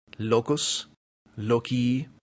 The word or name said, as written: locus